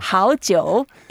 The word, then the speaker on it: 好久: 好久 also means something very important. 好 is good. – Yep. – 久. Of course, we know it's long time, but there's another character sharing exactly the same pronunciation. That's wine. – Ah. – So 好久 is also good wine.